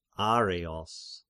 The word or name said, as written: Areios